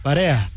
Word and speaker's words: Парея: Къде е ударението? Парея? Парея. Значи да, имах правилни усещания.